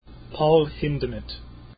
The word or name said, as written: Hindemith